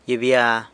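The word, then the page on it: gyibya: San Lucas Quiaviní Zapotec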